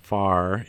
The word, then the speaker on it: PHARE: So for people who are listening that are interested in learning more, although the organization is pronounced FARE, it's actually spelled P-H-A-R-E if you're going to Google it online.